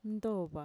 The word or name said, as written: ntova